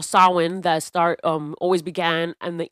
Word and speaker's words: Samhain: Which I didn't think that was a correct pronunciation, because this thing is spelled, S-A-M-H-A-I-N. So for an M to make a W sound, it was like, what's going on?